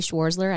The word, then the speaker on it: Schwerzler: Again, if you want to connect with us, you can feel free to connect with me on LinkedIn, Shelly Schwerzler. I know it's a complicated name. – Well, let's spell it. Yeah. – S-C-H-W-E-R-Z as in zebra, L-E-R, mm-hmm.